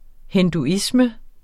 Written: hinduisme

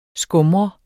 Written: skumre

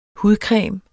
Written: hudcreme

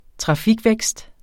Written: trafikvækst

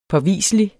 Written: påviselig

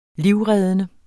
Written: livreddende

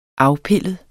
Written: afpillet

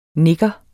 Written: nigger

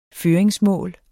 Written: føringsmål

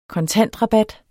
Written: kontantrabat

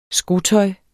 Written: skotøj

